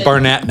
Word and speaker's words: Barnett: And Barnett, I can pronounce Barnett. That's correct, isn't it? – I say Barnett now.